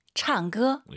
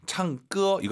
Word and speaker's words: 唱歌: 唱歌. – 예. 쌍기역보다는 좀 기역과 쌍기역의 느낌인 것 같아요. 가운데 느낌, 창조.